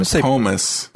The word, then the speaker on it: Pomus: Doc Pomus.